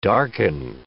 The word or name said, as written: darken